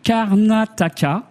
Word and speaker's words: Karnataka: La région, c'est le Karnataka. Je le prononce doucement, je le prononce bien. Très bien.